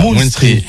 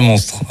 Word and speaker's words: Moonstreet: Et Moonstreet, ça s'écrit M-O-O-N-S-T-R-E-E. – C'est ça, Moonstreet, et pas monstre.